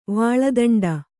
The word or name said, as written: ವಾಳದಂಡ